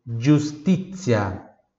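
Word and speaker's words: giustizia: Justitia. Justitia. Justitia. Pronunciar pura do T, atenção também, tá? E Z com som de TS. Justitia.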